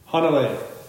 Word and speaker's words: Hanalei: Hanalei.